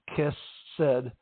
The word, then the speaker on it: hesed: So one of the Hebrew words for love is hesed, pronounced kesed, which is actually a difficult word to translate in English.